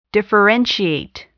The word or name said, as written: differentiate